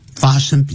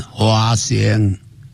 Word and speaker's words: 发生: 但在福建这个地方你看本来讲福建啊我刚刚念福建的地方尤其是漳州泉州的时候讲发生比较困难会念成花生啊整句话就昨晚在该宿花生啊，那声音听起来很亲切啊，就是觉得蛮棒的，还是念发声会比较好。